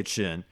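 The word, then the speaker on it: Qin: We find out that this whole thing has something to do with an apartment that they wanted, her and her husband, whose name is spelled Q-I-N. I feel like they were saying Chin. – Yeah, I think so. – Okay, yeah. – Okay, so let's say Chin.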